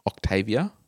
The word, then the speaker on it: Octavia: One of us have driven the Octavia. Is that how you pronounce it? – Yes.